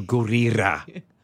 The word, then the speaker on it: Gurira: Gurira. – Mm-hmm. – She taught me how to say it. She was like, double roll the R. Do Gurira.